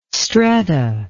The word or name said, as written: stretta